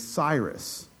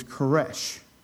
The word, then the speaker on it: Koresh: when it's anglicized and pronounced in English, is Cyrus. But the Aramaic version of that is Koresh.